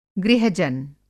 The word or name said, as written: गृह-जन